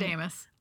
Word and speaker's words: Archidamus: So in Act 1, Scene 1, the guy, Archidamus, he's not important. I don't know how to say his name. He's not important. – Archidamus. – Okay, great.